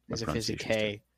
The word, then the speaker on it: Metaphysicae: Alan Walter is Summula Metaphysicae. I'm sorry. – Metaphysicae. – I've heard with classical pronunciation, it's Kai. – It is with Kai.